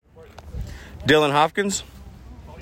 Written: Dylan Hopkins

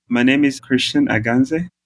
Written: Christian Aganze

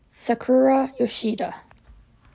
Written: Sakura Yoshida

